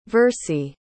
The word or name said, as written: versie